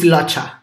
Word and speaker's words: Locha: It's Locha. – Loca Mesh. – Yeah, it's like Locha. Locha Mesh.